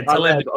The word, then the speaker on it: Taleb: Yeah, Taleb.